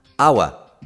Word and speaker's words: hour: C'è un'ora, perché c'è l'H ma non si pronuncia l'H. Hour, che è uguale, identico di hour come nostro.